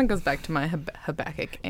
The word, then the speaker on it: Habakkuk: Did I say it right? – You did. Good job. – Yes. – Of course, we're all assuming that's how it's said, because there is nothing known about Habakkuk outside the prophecy of Habakkuk.